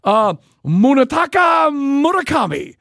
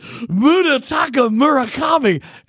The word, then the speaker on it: Munetaka Murakami: Munetaka Murakami. You say it like Harry Carey. Here comes to the plate, Munetaka Murakami.